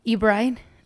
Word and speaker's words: Ebright: Ebright, ¿lo pronuncié bien? – Así es. Sí. Tal cual.